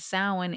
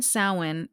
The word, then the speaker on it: Samhain: The word Samhain is pronounced sow, as in cow, in, and is sometimes written in the old Scottish Gaelic form as Samhain, which is pronounced not the way I just read it.